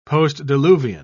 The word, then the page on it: postdiluvian: British English